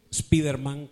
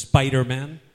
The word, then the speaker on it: Spider-Man: Yo era de Spiderman, como dicen en España, Spiderman. En inglés es Spider-Man. Y algunos se confunden cuando oyen Spider-Man. Es la pronunciación correcta de Spider-Man.